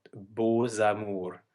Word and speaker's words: Beaux Amours: And another one that doesn't sound like the original letter is Beaux Amours, where we have a silent X that turns into a Z, Beaux Amours.